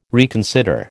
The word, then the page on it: reconsider: American English